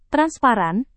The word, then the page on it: transparan: Indonesian